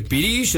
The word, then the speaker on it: pirix: Pirix. O sea, pirish, se dice pirish, es una palabra muy hermosa.